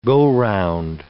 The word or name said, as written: go-round